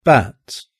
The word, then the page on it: bat: British English